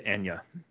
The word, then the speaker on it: Anya: She pronounces it Anya.